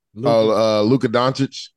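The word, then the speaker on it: Luka Dončić: Say his name for me. Who we just talking about? Dallas. – Luka Doncic. – Yes.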